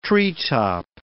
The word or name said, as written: treetop